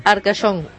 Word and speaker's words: Arcachon: Arcachon.